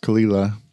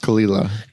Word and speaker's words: Caol Ila: Okay, so that's spelled C-A-O-L-I-L-A. – Mm-hmm. – And it's pronounced Kalila. – Kind of like Cole with the A and the O switched. – Yeah. – And like if you were to say Kila. It phonetically, it'd be like K-L-I-L-A. – Yeah. Wow. – But I've heard it pronounced Kalila.